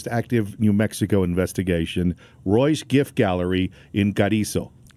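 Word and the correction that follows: Carrizozo: Carrizoso. – Carrizoso. – Yes. Yeah. I'm sorry. Did I misspell it there? – No, I mispronounced it here.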